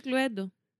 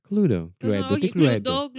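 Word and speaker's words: Cluedo: Το οποίο λέγεται κλουέντο. Προφέρεται. – Στα αγγλικά. Ναι. Εγώ το λέω κλούντο πάντω. – Κλούντο. – Κλουντό, κλουντό δεν λέγεται σίγουρα.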